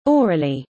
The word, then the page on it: aurelie: British English